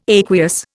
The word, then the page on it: aqueous: Indian